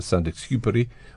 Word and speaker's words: Saint-Exupéry: this story by Antoine de Saint-Exupéry, or however you pronounce it. How do you pronounce it? – I think you did very well.